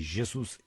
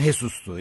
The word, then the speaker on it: Jesus: Dinleyicimiz demiş ki Jesus İspanya olarak okunursa J'nin okunuşu H'dir. Evet ama Jesus İspanyol değil, Portekizli. – Portekizlilerde de J J'dir.